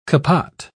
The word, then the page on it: capot: British English